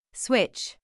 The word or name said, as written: switch